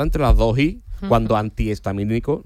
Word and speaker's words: antihistamínico: Y hay otra que también es así, digo que yo siempre la he dicho mal, que es antihistamínico. – Es verdad, sí. es cierto. – Lleva H intercalada entre las dos.